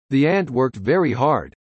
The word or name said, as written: world